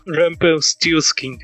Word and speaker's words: Rumpelstiltskin: Rumpelstiltskin.